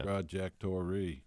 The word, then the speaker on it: trajectory: Trajectory. – Thank you. That's the word I was looking for. – You can't. – That's a three-syllable word. – Yeah. No, it's four. – I know. – Trajectory.